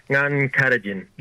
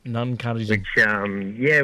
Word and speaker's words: Nungkatajan: How do you pronounce it? It's Nungani? – Yeah, pretty close, brother. It's Nungkatajan. – Nungkatajan.